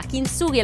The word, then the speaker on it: Kintsugi: Mentre, che differenza c'è tra Kintsugi e Kintsugi? Che una è sbagliata, una è giusta. Kintsugi è la pronuncia giusta del